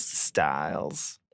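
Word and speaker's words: Sts'ailes: How's it spelled? – It's S-T-S apostrophe A-I-L-E-S. – That's not even, no. – Right. – Stales. – Stales. – Styles. – Yes.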